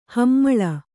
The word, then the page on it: ಹೆಮ್ಮೆಳೆ: hemmeḷe